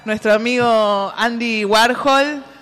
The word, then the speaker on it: Warhol: que me digan Andy por Warhol. A ver si abajo. – Nuestro Warhol se escribe UAJOL. Warhol.